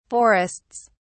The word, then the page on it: forests: British English